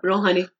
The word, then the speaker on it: Rouhani: Rouhani? – Yep, Rouhani.